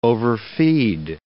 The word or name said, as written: overfeed